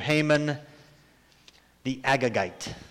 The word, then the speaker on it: Haman the Agagite: Enter Haman the Agagite. I know, it sounds like I've got a stuttering problem. That's how you say it, I guess. Haman the Agagite, he's descended from a king by the name of Agag.